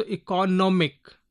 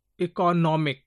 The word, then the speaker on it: economic: Example, if I say economic, so economic, E-C-O-N-O-M, N-O-M is given more stress in this syllable, economic. Comprehensive, hen is given little more, comprehensive. So S-I-V-E, end with S-I-V-E, before S-I-V-E, hen is there, comprehensive.